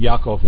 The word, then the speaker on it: Yaakov: I change those back to Avraham, Yitzhak, and Yaakov whenever I remember to, which is most of the time. I like to actually use the Hebraic pronunciation of those names.